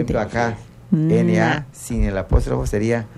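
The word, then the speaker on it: na': Na.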